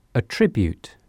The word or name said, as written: a tribute